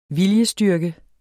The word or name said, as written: VILJESTYRKEN